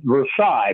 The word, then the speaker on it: Versailles: We make our home in a little town called Versailles, Missouri, and that's spelled V-E-R-S-A-I-L-L-E-S. Now, the French pronounce it Versailles, but we hillbillies up here call it Versailles, so, you know.